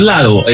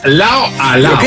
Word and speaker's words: Lao a Lao: le pertenece a Prince Royce la canción Lao a Lao. Así se escribe, no es Lado, es Lao a Lao.